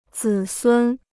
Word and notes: 子孙: zǐ sūn

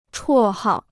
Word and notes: 绰号: chuò hào

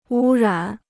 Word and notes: 污染: wū rǎn

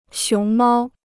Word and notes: 熊猫: xióng māo

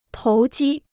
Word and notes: 投机: tóu jī

